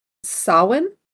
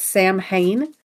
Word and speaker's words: Samhain: But it started as Samhain, and it's very commonly mispronounced as Sam Hane.